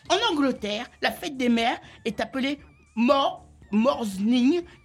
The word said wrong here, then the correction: Mothering Sunday: qui s'écrit M-O-R. – Oh là là, fais voir ta feuille. – Morsning, non, c'est... Non, voilà, Morsering, où ça? Fais voir, où ça? Mothering Sunday. – Voilà, Mothering Sunday, voilà.